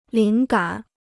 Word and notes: 灵感: líng gǎn

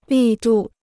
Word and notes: 力度: lì dù

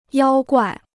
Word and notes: 妖怪: yāo guài

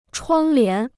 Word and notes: 窗帘: chuāng lián

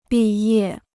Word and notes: 毕业: bì yè

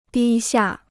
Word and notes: 低下: dī xià

